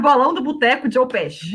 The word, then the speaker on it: Joe Pesci: Joe Pesci.